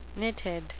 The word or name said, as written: nitid